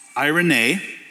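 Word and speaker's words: eirene: And so, in a Strong's Concordance, it's right here in your notes, that word peace in Galatians 5 is pronounced irene.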